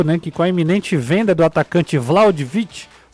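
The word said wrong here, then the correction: Vlahović: Vlaudic, qual é a pronúncia, Caio? Sabe? Vlaudic, Vlaudic, deixa eu, deixa eu abrir o, o microfone. Vlaovic, na verdade. Como é? Vla, Vlaovic. Vlaovic.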